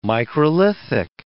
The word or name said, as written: microlithic